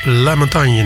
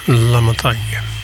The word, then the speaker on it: La Montagne: Niet Gilles Montagne, maar La Montagne. Waarschijnlijk moet je dat in het Engels uitspreken.